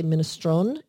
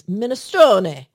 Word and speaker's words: minestrone: And I want to be clear on this, the E at the end, because some people say minestrone. It's actually, the E is pronounced and it's pronounced as an A.